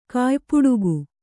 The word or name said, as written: ಕಾಯ್ಪುಡುಗು